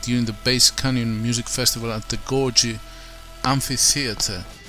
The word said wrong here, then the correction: Gorge: Am I pronouncing this well, Gorgie? – Beautiful. Oh, wait, no, you're talking about Georgia? – Is it Gorge, G-O-R-G-E? – G-O-R-G-E. – Anyway. – Gorge, yeah, you're right.